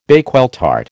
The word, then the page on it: Bakewell tart: American English